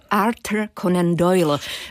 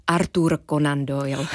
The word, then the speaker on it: Arthur Conan Doyle: A jeho literárním otcem není nikdo jiný než Sir Arthur Conan Doyle, nebo spíš tedy raději Sir Arthur Conan Doyle. – Když dovolíte, budeme ho vyslovovat česky.